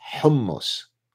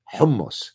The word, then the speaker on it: hummus: Don't hit me with hummus and hummus and all that bullshit. Pronounce the goddamn word properly. It's hummus, okay? Hummus.